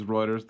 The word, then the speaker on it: Reuters: Is Reuters the correct way to say it? Awesome. – Yep. – Yeah, because you see the U and it throws you off a little bit.